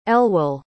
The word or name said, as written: elwell